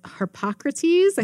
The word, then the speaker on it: Harpocrates: And she notices Harpocrates. I think I said that right. – You did.